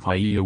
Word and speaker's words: paella: And finally, if you don't already know and you want to impress your friends, paella is pronounced paella with the E as in bet.